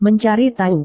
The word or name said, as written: mencari tahu